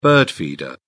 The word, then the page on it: bird feeder: British English